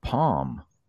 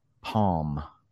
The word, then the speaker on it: palm: It's your palm. Palm. That's how you say it. Palm. The L is pretty much silent.